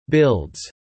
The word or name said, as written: builds